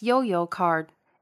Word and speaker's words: 悠遊卡: The Easy Card, the smart card used in the Taipei MRT system, is pronounced as Yo-Yo Card in Chinese.